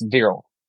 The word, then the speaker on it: Viral: It's Viral.